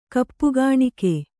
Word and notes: ಕಪ್ಪುಗಾಣಿಕೆ: kappugāṇike

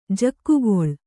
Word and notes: ಜಕ್ಕುಗೊಳ್: jakkugoḷ

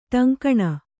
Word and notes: ಟಂಕಣ: Kannada; ṭaŋkaṇa

